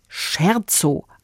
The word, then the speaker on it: Scherzo: Geschrieben wird es wie Scherzo, also wie der Scherz, aber eben italienisch ausgesprochen. Und dann heißt es Scherzo.